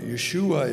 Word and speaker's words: Yeshua: Yeshua is actually the way it's pronounced, I understand. That's the way the Jews nowadays pronounce it, Yeshua.